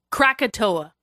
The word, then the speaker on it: Krakatoa: Krakatoa.